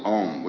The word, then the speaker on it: Om: Now this sacred syllable Om, which is repeated in all of the prayers of India, is composed of four sounds. One is Ah. Second is U, ow, and the third is M.